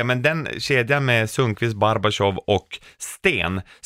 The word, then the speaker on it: Barbashov: Bodens stolthet som tillsammans med Barbashov. Jag säger det nu också. På ryska uttalas det Barbashov. Det är därför jag har gjort det.